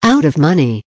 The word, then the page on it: out of money: American English